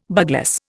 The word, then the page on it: bugloss: Indian